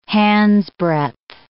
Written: handsbreadth

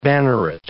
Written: banneret

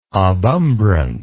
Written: obumbrant

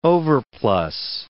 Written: overplus

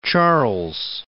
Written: Charles I

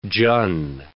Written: Jun

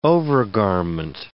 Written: overgarment

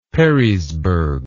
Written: perrysburg

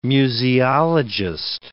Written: museologist

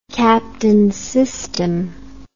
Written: captain system

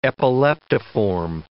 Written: epileptiform

